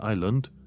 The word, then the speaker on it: island: Bineînțeles, atunci când se pronunță, nu uita că ai cuvinte gen island, în care S-ul nu se pronunță deloc.